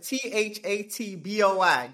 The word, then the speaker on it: THATBOY: So it's spelled THATBOY.